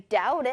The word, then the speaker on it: doubt: I doubt it. 이렇게 얘기하는 거거든요. 자, 따라서 말해볼게요. 친구들, 여기 D-O-U-B-T 이렇게 적혀있죠? B, 얘는 발음되지 않아요.